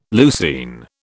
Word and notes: leucine: British English

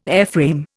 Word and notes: airframe: Indian

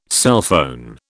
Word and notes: cellphone: British English

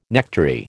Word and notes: nectary: American English

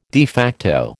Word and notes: de facto: American English

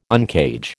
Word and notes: uncage: American English

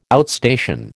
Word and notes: outstation: American English

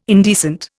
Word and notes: indecent: Indian

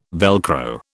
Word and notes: Velcro: British English

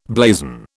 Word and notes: blazon: British English

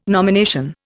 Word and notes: nomination: Indian

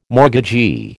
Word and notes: mortgagee: American English

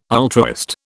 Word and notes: ultraist: British English

